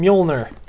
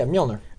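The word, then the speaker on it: Mjölnir: How do you pronounce his hammer again? – Mjolnir. – The Mjolnir. – Or Mjolnir. – But it's spelled so bizarrely. It's M-J-O-L-N-E-R, right? – Yeah, Mjolnir. – Mjolnir.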